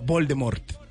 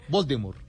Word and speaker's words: Voldemort: porque según ella no se pronuncia la T del final, sino que se pronuncia Voldemort, no Voldemort.